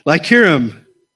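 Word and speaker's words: Illyricum: how do you pronounce that? Lycurum.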